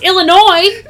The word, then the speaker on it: Illinois: They know how to do a silent S! – Obviously! Even though it's technically still pronounced, because it would be Noir, Illinois, but it's whatever. You Illinois. – obviously know how to do the silent S.